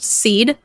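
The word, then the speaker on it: Sidhe: So the Tuatha De Danann were forced underground and eventually became the Shi. – I have also heard that pronounced seed, and there is actually a difference between the seed and the Tuatha De Danann.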